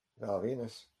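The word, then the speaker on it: Val Venis: Val Venus. Rhymes with penis.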